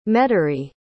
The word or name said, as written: Metairie